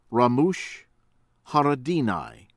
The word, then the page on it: Ramush Haradinaj: American English